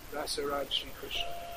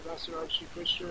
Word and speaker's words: Rasaraj Shri Krishna: So this is pronounced. It's Rasaraj Shri Krishna. They call it Rasaraj Shri Krishna in Telugu.